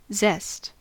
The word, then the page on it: zest: American English